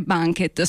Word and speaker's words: BNQT: E anche qui abbiamo un nome che si scrive in una maniera e si pronuncia in un'altra, e in effetti questa è la sigla BNQT, ma si dovrebbe leggere Banquet.